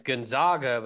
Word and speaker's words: Gonzaga: When referring to Gonzaga University, it's pronounced Gonzag, as in bag.